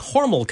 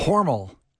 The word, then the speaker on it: Hormel: Hormel. – Hormel is how the family pronounced it. It just, when it went public, people started saying Hormel.